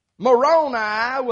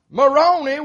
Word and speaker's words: Moroni: Moroni with a long I is a moron telling a lie. – Yeah. – Moroni with a long E is a moron with baloney.